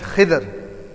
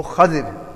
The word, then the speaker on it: Khidr: His name is Khidr or Khadir. You can pronounce both.